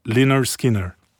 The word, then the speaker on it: Lynyrd Skynyrd: Porque é, ninguém sabia pronunciar Lynyrd Skynyrd, Skinner, mas é Lynyrd Skinner, eu acho.